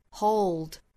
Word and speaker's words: hold: 但是如果在单词的尾部，或者是说在中间的部分后面没有元音的情况，那我们就要发出一个 dark l，也就是舌尖去抵上齿龈，舌头中部下陷，o 这样的发音 ideal o。 Hold 尤其是这个 hold 这个词里面是含有舌侧音，但是很多人会把它忽略掉，直接会发成 hold，很像我们汉语这个后面的后这个音啊。